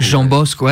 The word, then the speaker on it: João Bosco: João Bosco, ouais, moi j'aurais plutôt une prononciation éventuellement un peu à la portugaise et non pas. C'est peut-être plus facile encore, enfin disons facile, c'est peut-être moins. La prononciation est peut-être moins complexe en brésilien, plus simplifié. Exactement, peut-être que c'est moins nasalisé.